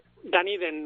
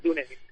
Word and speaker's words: Dunedin: Pues yo me encuentro en Dunedin, Nueva Zelanda. O sea que qué grande. – Vosotros lo pronunciaréis Dunedin.